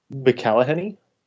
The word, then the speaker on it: McElhenney: I went with, I hope I pronounce his last name correctly, Rob McElhenney.